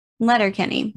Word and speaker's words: Letterkenny: Letter Letter. – Right. – Kenny. – Oh, Kenny. – Letterkenny. – Letterkenny. – One word.